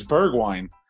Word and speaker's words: Bergwijn: Oh, you said it wrong. Oh my god, that's why I'm so confused. Oh, it's Bergwijn.